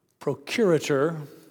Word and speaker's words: procurator: Pontius Pilate, I usually say the Roman ruler because procurator. My wife reminds me, it rhymes with alligator.